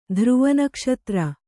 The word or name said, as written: ಧ್ರುವನಕ್ಷತ್ರ